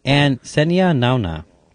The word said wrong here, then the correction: Nanua: Nanua. Sorry, what the fuck did I say? Senia Nanua.